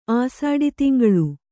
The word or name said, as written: ಆಸಾಡಿತಿಂಗಳು